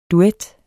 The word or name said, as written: duet